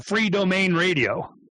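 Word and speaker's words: Freedomain: You know, how do you say that? I call it free domain radio. – That's perfect. That's just great, because it's freedom is the main thing, and you want to be free in your own domain.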